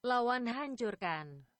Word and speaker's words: Lawan Hancurkan: All right, next up we have Tulang Besi with the track Lawan Hancurkan. It's an Indonesian. And? – Lawan Hancurkan. – There you go. A little better pronunciation than I was providing.